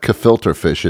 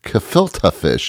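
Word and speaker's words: gefilte fish: It's not kefilter fish. It's kefilta fish. You have to say it right.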